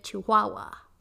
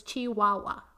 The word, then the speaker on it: Chihuahua: I totally mispronounced. It's Chihuahua. I keep saying Chihuahua. Like a fucking white person. I literally just had Sam teach me how to pronounce Chihuahua in Spanish.